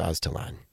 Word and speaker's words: Aztalan: I'm pronouncing that correctly, right? – That's correct. – I used to say Aztalan, and everybody's like, it's Aztalan.